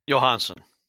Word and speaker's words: Johansson: How do you say his last name? – Johansson. – Yeah.